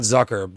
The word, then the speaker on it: Zucker: And I know, by the way, I always hear it's called, I always hear him pronounced Zucker, not Zucker, but it's Zucker. – Yeah, oh, it's Zucker. – That's how I've heard it.